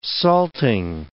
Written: salting

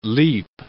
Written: leap

